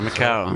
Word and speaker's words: Macau: Macau, is that how you say it? Macau? – Yeah, Macau.